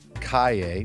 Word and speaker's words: Calle: Kalle, which is spelled C-A-L-L-E.